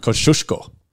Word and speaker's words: Kosciuszko: So Kosciuszko is pronounced how? – Kosciuszko. – Kosciuszko.